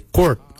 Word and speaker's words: goord: For heart, we say kort. Your kort is your heart. Kort is spelled G-O-O-R-D, kort.